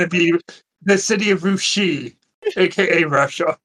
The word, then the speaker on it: Russia: Oh, and the best way thing about it is it'll get really messy because it's pronounced Rushi for that little city in Ohio.